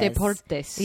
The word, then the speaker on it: Deportes: I hear more about Fox Sports and Fox Despuerto. How do you say Deportes? – Deportes. – Yeah. It's Fox. Fox Deportes.